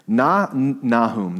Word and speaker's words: Nahum: Nahum, that's how we pronounce it in Haiti.